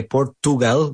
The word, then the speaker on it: Portugal: Very good, the way that Rubén pronounced Portugal there as well. We don't say Portugal, we say Portugal. – Siempre me acuerdo de que utilizar la che. Es Porchu, chu, chu, no tu.